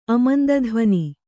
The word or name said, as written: ಅಮಂದಧ್ವನಿ